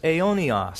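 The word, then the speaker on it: aionios (αἰώνιος): The word eternal is aionios. Say that. Aionios. And I'll tell you how to spell it in English. It's A-I-O-N-I-O-S. Aionios.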